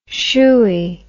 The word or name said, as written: shoey